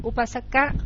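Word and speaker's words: Upāsaka: Mình hay gọi cái nghe chữ chữ này là đọc tiếng Pali đó, tiếng là Upasaka, nguyên chứa của chia mẫu là Upasaka, mà chữ A ngắn thì sao ta không có đọc. Người ta đọc là Upasak, và khi Upasak mình phiên âm ra gì?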